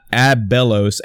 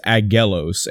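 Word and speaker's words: Aggelos: And I did mispronounce it last episode. It's not Abelos, A-B-B. It's Agelos. And I'm maybe pronouncing the name wrong, but it's A-G-G-E-L-O-S.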